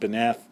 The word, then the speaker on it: beneath: Beneath doesn't rhyme in that stanza, but in the next stanza it rhymes with death and breath. Again, he would have pronounced it beneth.